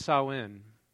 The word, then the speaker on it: Samhain: Now it's, it's pronounced it's pronounced Samhain, okay, but it's spelled S A M H A I N.